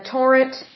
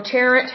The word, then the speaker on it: Tarrant: Torrent, or Tarrant, however you want to pronounce that. T A R R A N T is the spelling on that one.